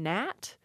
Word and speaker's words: gnat: So you had that first, the initial K, and then the G-H representing a H sound, so knicht, and the initial G in gnat was actually pronounced at one point, so it was a gnat.